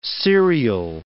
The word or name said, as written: serial